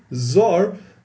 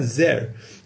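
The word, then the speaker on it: Zer (זר): If you look in the Chumash, it's written without the Yud, as if it says Zor, foreign or strange. But it's we know to pronounce it as Zer, as a crown.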